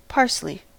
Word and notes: parsley: American English

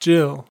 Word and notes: jill: American English

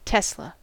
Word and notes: tesla: American English

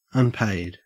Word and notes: unpaid: Australian English

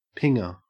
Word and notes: pinger: Australian English

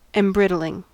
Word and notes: embrittling: American English

